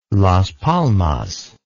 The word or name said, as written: Las Palmas